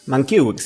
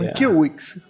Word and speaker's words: Mankiewicz: A ver, Mankiewicz sería. – Mankiewicz, sí.